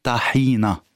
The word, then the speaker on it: tahina: Alors je vais me remettre à la langue arabe écrite, on dit tahina, donc c'est tahina. Après on peut dire en libanais avec l'accent tahini, tahina, donc tout se dit, mais si on écrit le mot tahina en arabe, c'est tahina, et c'est comme ça qu'on devrait le prononcer tahina, avec un A.